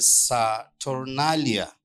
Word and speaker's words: Saturnalia: It can be traced from the Roman pagan festival called the Saturnalia. If you want to spell it, it's S-A-T-U-R-N-A-L-I-A, Saturnalia.